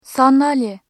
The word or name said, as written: sandalye